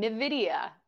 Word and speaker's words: Nvidia: How many people still say the company name wrong? Nvidia. – Maybe I'm saying it wrong. – No, exactly. – You're saying it right.